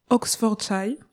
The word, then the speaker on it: Oxfordshire: Radiohead est un groupe de rock britannique, originaire d'Abington, dans le Oxfordshire, j'espère que je le dis bien. C'est une région. – Tu le prononces très bien.